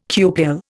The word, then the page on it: cupule: Indian